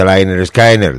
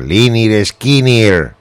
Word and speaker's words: Lynyrd Skynyrd: Se pronuncia Liner Skinner, Liner Skinner.